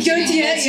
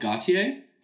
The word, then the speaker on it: Gotye: I think it's pronounced Gotye. But that's the name of the guy. Because it's like Cher. You know, it's like a Cher or Lady Gaga.